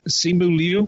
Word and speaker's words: Simu Liu: I'm trying to Simu Liu. Mm-hmm. Am I pronouncing? Yep.